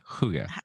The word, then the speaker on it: hygge: Hygge. – What? Okay. – The BBC says hygge. That's the BBC.